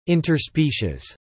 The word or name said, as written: interspecies